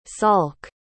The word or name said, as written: salk